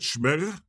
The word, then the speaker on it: Schmegger: Is that how you say it, Schmegger? – Yeah. – Oh, I thought it was Schmegegi.